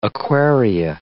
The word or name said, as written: aquaria